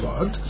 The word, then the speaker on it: Lod: Rav Lezer, the city of Lod, is actually the proper way to pronounce it. Rav Lezer lived in the city of Lod, and he says a person who brings a get from Kfar Ludim to Lod, he must say Bifanei Nechtam, Bifanei Nechtam.